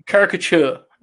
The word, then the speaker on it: caricature: It's close. Caricature. – Okay. – You need to split the C and the T, but it's fine. You're close.